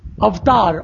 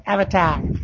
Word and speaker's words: Avatar: And the name of the movie is Avatar, not Avatar. – Exactly. It's not Avatar. Even when I pronounce it here, I make sure that I'm pronouncing Avatar. We are cheated so many times for doing wrong pronunciations for English words. And then they take a Sanskrit word and completely screw it up.